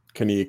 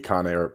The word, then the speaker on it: Cannae: Cannae, Cannae, both are acceptable pronunciations.